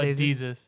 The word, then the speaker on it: Desus: Um, do you know Dez Desus? Uh, Desus. Desus. Okay, yeah.